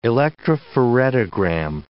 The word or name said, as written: electrophoretogram